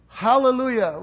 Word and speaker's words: Hallelujah: It's spelled, transliterated into from the Greek, but it's really a Hebrew word. Hallelujah. You know, when you say hallelujah, it's closer to the Hebrew.